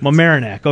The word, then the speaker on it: Mamaroneck: Mamaroneck, I think. Mamaroneck.